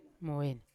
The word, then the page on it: mavena: Yawa